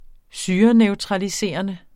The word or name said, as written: syreneutraliserende